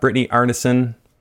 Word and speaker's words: Brittany Arnason: I have Brittany Arnason Said it right? – You said it right.